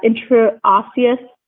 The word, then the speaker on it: intraosseous: The section 1302 permit holder shall use, oh boy, I'm going to butcher this, intraosseous access exclusively for emergency situations.